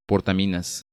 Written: Portaminas

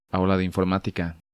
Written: Aula de Informatica

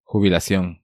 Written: Jubilacion

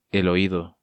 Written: El Oido

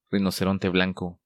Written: Rinoceronte Blanco

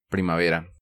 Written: Primavera

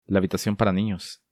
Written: La Habitacion para ninos

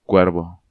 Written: Cuervo

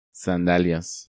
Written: Sandalias

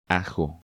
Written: Ajo

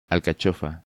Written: Alcachofa

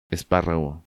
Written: Esparrago

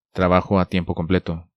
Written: Trabajo a tiempo completo

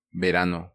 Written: Verano

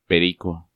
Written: Perico

